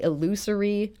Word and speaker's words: illusory: Tell us about the illusory, I'm sure I've mispronounced that, truth effect. – I think you said that right. – Wow. – I would be surprised if there's another way to say it.